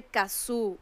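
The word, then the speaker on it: Cazzu: Esto es de Kazoo, otra de las mayores representantes del trap. Le llaman la reina del trap algunos. Kazoo se escribe C-A-Z-Z-U y esto se llama Kila. – Doble Z.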